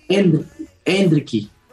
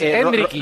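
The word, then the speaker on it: Endrick: ¿Hendrik o Hendriki? – Hendriki. – No acertó nadie. – Sí, Melchor. – Ya te lo dije. – Melchor dijo Hendriki. Sí. – No, pero él. Es Hendriki. Rodrigo ha dicho Hendriki con acento en la primera, ¿eh? – Sí, lo dijo así.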